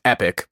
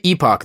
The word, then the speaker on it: epoch: That word is supposed to be pronounced epic, by the way, as in a time period. I will say epoch, though, how it's spelled, to differentiate it from something that you yell when your buddy lands a 42 foot jump on a snowboard.